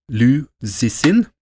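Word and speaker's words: Liu Cixin: Liu Zixin, kineser Jeg vet ikke hvordan man skal uttale navnet. – Hørtes helt greit ut.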